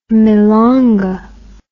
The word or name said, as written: milonga